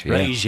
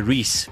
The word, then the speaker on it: Reis: Rage, yeah. Yeah, you did. – Actually, because when I first saw it, I wanted to say Reese, but then I heard it. It's spelled sort of Reese. R-E-I-S.